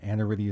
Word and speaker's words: Aniridia: Aniridia is spelled A-N-I-R-I-D-I-A. You're nodding your head. I got it close to right? Okay.